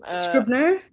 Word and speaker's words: Scribner: Scribner.